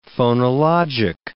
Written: phonologic

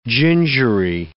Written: gingery

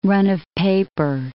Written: run-of-paper

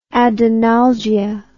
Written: adenalgia

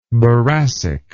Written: boracic

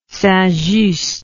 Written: saintjust

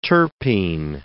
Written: terpene

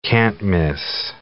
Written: can't-miss

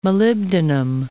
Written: molybdenum

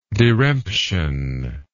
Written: diremption

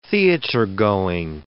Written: theatergoing

